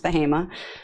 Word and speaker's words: Bahama: So I am in a very rural area of North Carolina called Bahama, spelled Bahama, but pronounced Bahama.